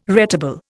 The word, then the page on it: retable: Indian